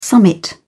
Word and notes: summit: British English

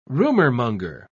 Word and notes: rumourmonger: British English

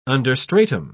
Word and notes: understratum: British English

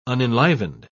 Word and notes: unenlivened: British English

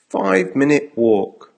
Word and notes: 5 minute walk: American English

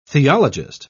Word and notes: theologist: British English

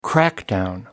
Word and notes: crackdown: American English

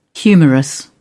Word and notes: humerus: British English

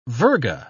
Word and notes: virga: British English

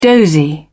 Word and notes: dozy: British English

